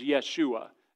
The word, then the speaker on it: Yeshua: But they don't use the J sound, so Jesus would not be called Jesus in that day. His name was Yeshua. That's how the Jews would pronounce his name.